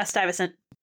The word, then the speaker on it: Stuyvesant: Is that pronounced Stuyvesant? – Yeah, Stuyvesant. Okay.